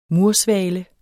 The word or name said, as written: mursvale